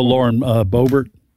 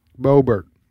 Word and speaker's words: Boebert: How do you pronounce the congressman? – Oh, Lauren Boebert. – Boebert. So Boebert, the congresswoman from Colorado, the Republican.